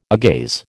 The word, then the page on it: agaze: American English